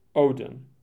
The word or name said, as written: Odin